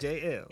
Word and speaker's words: Jay Elle: Jay Elle.